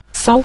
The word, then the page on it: sao: sao5